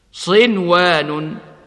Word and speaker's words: صنوان: وكما ننطق بالنون الساكنة مظهرة قبل الواو من كلمة أيضا هكذا: صنوان وغير صنوان يسقى بماء واحد، صنوان.